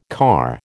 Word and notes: car: American English

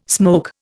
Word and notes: smoke: Indian

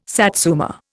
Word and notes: satsuma: Indian

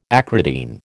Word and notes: acridine: American English

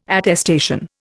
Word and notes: attestation: Indian

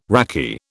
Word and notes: raki: British English

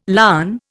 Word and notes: larn: Indian

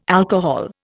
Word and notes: alcohol: Indian

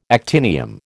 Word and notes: actinium: American English

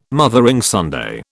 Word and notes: Mothering Sunday: British English

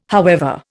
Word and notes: however: Indian

